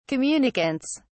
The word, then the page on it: communicants: British English